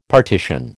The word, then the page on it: partition: American English